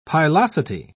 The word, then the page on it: pilosity: British English